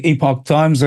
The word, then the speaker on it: Epoch Times: Some people pronounce it Epoch Times. I know it's the Epoch Times, and tremendous articles coming out of there.